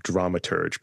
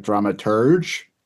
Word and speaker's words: dramaturg: Well, often people say, is it dramaturge or is it dramaturg? So I think that's like the number one is like dramaturge is the wrong way of saying it, and dramaturge.